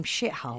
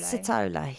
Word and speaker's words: Sithole: Yeah, it's And we was going to call him Shithole. – Shithole, yeah. he's an arsehole, isn't he? – Yeah. – But yeah, it's pronounced Sithole. – Sithole, yeah.